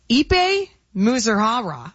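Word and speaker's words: Ippei Mizuhara: On paper, Ipe Muzuhara. I have not said his name in a long time. With Shohei Ohtani's, Did I say it wrong? – You're good. Go ahead.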